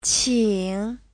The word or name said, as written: qing3